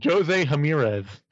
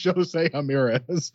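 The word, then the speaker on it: José Ramírez: That's not how J is pronounced. – Jose Ramirez. – Yes, Jose Ramirez.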